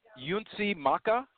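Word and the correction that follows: Unci Maka: is that how you pronounce Mother Earth? Yuntsi Maka? – Unchi Maka. – So besides Unchi Maka.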